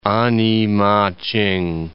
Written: A-ni-ma-ch'ing

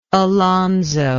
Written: Alonso

